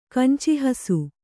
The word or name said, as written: ಕಂಚಿಹಸು